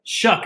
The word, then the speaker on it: Shough: Is it Shough or Shoe or how you pronounce that? – Shuck, actually.